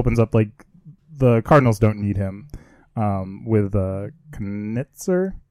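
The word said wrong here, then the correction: Knizner: is that how you say his name? – Knisner.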